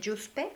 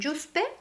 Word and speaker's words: Yuzpe: De hecho, si quieres investigar más sobre el régimen Yuspe... Yuspe se escribe con Y-U-Z-P-E.